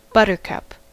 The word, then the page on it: buttercup: [ˈbʌt.ɚ.ˌkʌp]; /ˈbʌt.ə.kʌp/